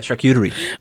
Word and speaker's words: charcuterie: Uh, charcuterie.